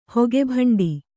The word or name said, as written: ಹೊಗೆಭಂಡಿ